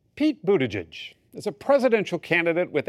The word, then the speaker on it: Buttigieg: It's Buttigieg, as in boot edge edge.